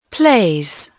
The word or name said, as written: plays